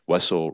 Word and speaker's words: Wessel: Wessel rhyming with Gessel, but with a W.